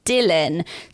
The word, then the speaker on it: Dillon: But the one I really liked as well is Dylan, spelled D-I-L-L-O-N.